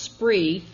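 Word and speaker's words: Spraoi: That's pronounced Spree, and in Gaelic it means play.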